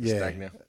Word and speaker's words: Castagna: How do you say his last name? Castagna. Yeah. It's almost like Montagna. I don't know how you pronounce it properly, but he didn't get much.